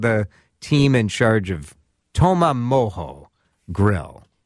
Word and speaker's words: Toma Mojo: I said it right. – Yeah, you said it right. – It's spelled T-O-M-A and then M-O-J-O. And Paul Backer is here along with Chris Neal. Paul, people make it Toma Mojo, don't they? – Yeah, they like to say Mojo.